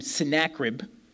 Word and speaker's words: Sennacherib: So this king of Assyria, I pronounce his name Sennacherib. You can pronounce it however you like.